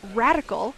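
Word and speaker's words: Radicle: She's the founder of Radical, which is spelled radical like particle, C-L-E at the end.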